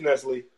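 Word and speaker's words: Nestlé: Completely butchered Nestle. – I've never knew how to say it, honestly. – It's Nestle. – It's Nestle. I said Nestle.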